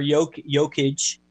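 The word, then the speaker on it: Jokić: Um, well, obviously Jokic is going to be the or Jok Jokic, depending on your pronunciation. I won't read. I won't say it the way it's spelled.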